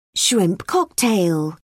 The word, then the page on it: shrimp cocktail: British English